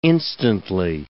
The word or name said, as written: instantly